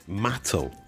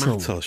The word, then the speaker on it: Mattel: Mattel. – Mattel. – It's Mattel. She treated me like an absolute idiot, going, you're pronouncing it wrong, it's Mattel.